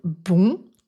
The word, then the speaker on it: bon: Quand on prend l'adjectif « bon » tout seul, on le prononce avec le son « on » nasal. Cependant, la voyelle nasale « on » disparaît quand on fait la liaison en « n » avec le mot suivant. On prononce donc « bon » avec un son « o » ouvert, « bo ». On parle de dénasalisation.